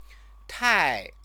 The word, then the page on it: tai: Chinese